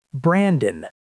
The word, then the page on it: Brandon: American English